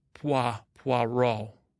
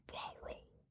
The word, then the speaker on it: Poirot: Tisha said that the guy, Agatha Christie's hero, is pronounced Poirot, which I've been doing in my spare time. I think it's easier for me to say French words like whisper, Poirot. Poirot is the hero.